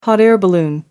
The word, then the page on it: hot-air balloon: American English